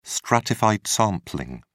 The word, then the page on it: stratified sampling: British English